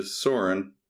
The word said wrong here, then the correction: Sirion: Sirion. That's how it's pronounced.